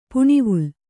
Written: ಪುಣಿವುಲ್

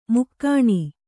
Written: ಮೈಗಣ್

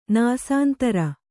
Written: ನಾಸಾಂತರ

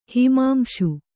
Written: ಹಿಮಾಂಶು

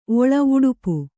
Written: ಒಳ ಉಡುಪು

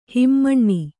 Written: ಹಿಮಾನಿ

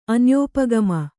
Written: ಅನ್ಯೋಪಗಮ

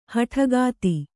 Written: ಹೆಡಕತ್ತು

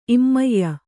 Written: ಇಮ್ಮೆಯ್

